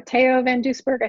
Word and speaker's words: Theo van Doesburg: This one's from the Netherlands, founded by Theo van Doesburg, or Theo van Doesburg, I think is actually how you say it.